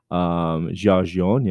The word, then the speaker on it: Giorgione: Giorgione, yeah, sure.